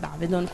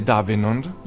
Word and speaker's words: Avedon: Avedon, quindi... – Cioè, ma ha influenzato tantissimo. – Sì. – Io da uomo mi inserisco, visto che qui si parla tanto di moda. Come si scrive Davenon? – No, Avedon!